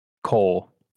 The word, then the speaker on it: Kroll: Is that how to say it? Kroll? – Cole. – Cole. There's no R? – Yeah, just Cole.